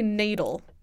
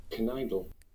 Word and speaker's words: knaidel: I'm going to say nadel. K-N-A-I-D-E-L. – What? – Which is another word for a Jewish dumpling. – Knidel. – Knidel. – Knidel. Well, at least you hear the K, I mean.